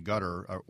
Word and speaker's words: Qatar: Well, the biggest one was in Qatar, or Qatar, depending on how you pronounce it, in 1996.